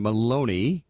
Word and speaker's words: Maloney: Oversight Committee Chair Representative Carolyn Maloney, which rhymes with baloney, pushed back on his remarks in a statement to CNBC, accusing him of seeking to protect the company's profits.